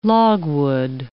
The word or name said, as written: logwood